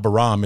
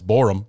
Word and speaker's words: Borum: And then, you know, we saw Larry Borum, who I've figured out that I've been pronouncing his last name wrong all this time. It's not Baram, it's Borum, apparently.